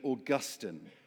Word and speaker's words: Augustine: And I want to start with a little story from the great theologian Augustine. That is how you say it.